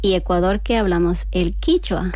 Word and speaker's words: quichua: Claro, por eso me has dicho, ay, yo pensaba que a lo mejor que lo había pronunciado mal, o sea, no. – ¿Qué es que es diferente el quichua? – Sí, sí, el quichua es de Ecuador y el quechua es de Perú.